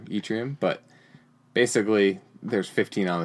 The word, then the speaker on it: yttrium: Yttrium. Yttrium. – Yeah. I believe is how it's pronounced.